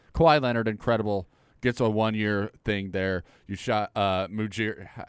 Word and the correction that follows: Masai Ujiri: Masai Ujiri. – Yeah, that's it. Masai Ujiri.